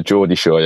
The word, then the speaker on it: Geordie Shore: Yeah, Geordie Shore, yeah.